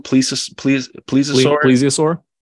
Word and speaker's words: plesiosaur: Plesiosaur.